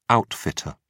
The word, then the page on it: outfitter: British English